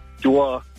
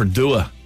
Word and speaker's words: Duagh: What's it called? – Dewar. – Dewar. I thought it was DeWalt Hand Tools. I was like, that's cool. They sponsor a whole town. Dewar. – How do you spell that? – D-U-A-G-H.